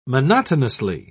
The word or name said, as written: monotonously